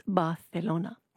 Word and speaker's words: Barcelona: So the other thing I'm insufferable about is how much I love, and because I've been there now, I can pronounce it like this, Barcelona.